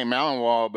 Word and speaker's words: Malinois: Is it Malinois? – Yeah, well, we say Malinois, but it looks like Malinois. I mean, I guess. – It's French. Yeah. You have to say Malinois and pair it with a fine wine.